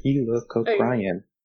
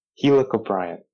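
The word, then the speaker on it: Helicoprion: So I know from what I've seen from you that the I still can't pronounce it. Helicobrion. Was that right? – Mm-hmm. – Okay, so I got it down. I understand that it's on confidence. – Helicobrion. There we go.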